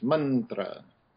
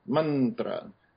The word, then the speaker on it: mantra: So we already explored that when we did So in a word like mantra, the first syllable will be emphasized, mantra, mantra, because it's built into the language that if you have a compound consonant, that first syllable will be emphasized, mantra.